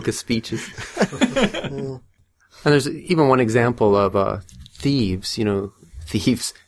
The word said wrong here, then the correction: Thebes: How do you pronounce that? Thebes? – I read Thebes.